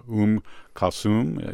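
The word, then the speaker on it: Oum Kalsoum: There's a best of Umm Kulthum. Is that how you say your name? – Yep. – And it's O-U-M, new word, K-A-L-S-O-U-M.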